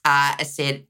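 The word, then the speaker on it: Aserejé: Okay, well, the ketchup song, which was originally called Asere. Yeah. That's how it's pronounced.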